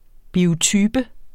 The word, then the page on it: biotype: [ ˈbiːoˌtybə ]; [ bioˈtyːbə ]; [ bioˈtybə ]